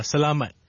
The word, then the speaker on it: salamat: How do you say thank you in Filipino? – It's salamat.